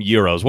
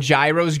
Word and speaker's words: gyros: I call them euros. Where's your pronunciation on that? – I always called them gyros growing up. And then like I got to college and learned, oh, wait, it's actually pronounced euro.